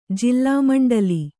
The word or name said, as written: ಜಿಲ್ಲಾಮಂಡಲಿ